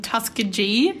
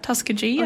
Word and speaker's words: Tuskegee: Mine is very much more superficial than that, but I just remember she was born in Tuskegee, and I just remember how much we laughed pronouncing that at the time. Or that's how you say it, right? Tuskegee? – Or Tuskegee? Tuskegee? – Exactly, okay.